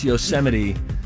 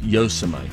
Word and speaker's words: Yosemite: Also, hey, also it's pronounced Yosemite. This is because in August, President Trump mispronounced it as Yosemite.